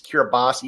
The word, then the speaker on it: Kiribati: Same way with the way Kiribati is pronounced. It's Kiribati, even though there's no S to be found. The T-I is pronounced like an S.